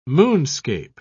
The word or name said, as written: moonscape